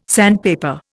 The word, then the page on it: sandpaper: Indian